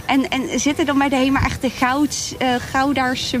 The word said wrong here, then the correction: Goudse: mensen, zeg ik dat goed? – Oh ja, Goudse mensen.